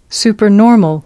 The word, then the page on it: supernormal: American English